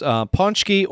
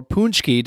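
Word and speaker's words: pączki: I think a very common one that most Michiganders know of, and I think a lot of other Midwesterners, and they pronounce this food right as well, is ponchki or punchki, depending on who you ask.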